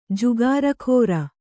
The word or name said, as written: ಜುಗಾರಖೋರ